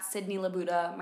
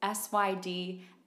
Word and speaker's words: Sydnee LaBuda: Okay, well, my Instagram handle is at Sydnee LaBuda. My name is spelled weird, so it's SYDNEELABUDA, Sydnee LaBuda.